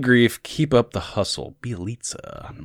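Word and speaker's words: Bjelica: Bielitsa. – Bielitsa. When you actually write it, it's like, whoa, I can't believe that it's pronounced that way. Bielitsa.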